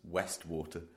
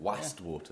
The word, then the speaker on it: Wastwater: You trying to pronounce Westwater? – No, Wast. – Okay. – It's spelt with an A. – Oh, right. Wastwater. – Yeah. Wast.